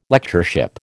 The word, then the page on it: Lectureship: American English